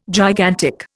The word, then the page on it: gigantic: Indian